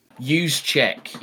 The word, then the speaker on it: Juszczyk: Kyle Juszczyk. – Juszczyk, yes.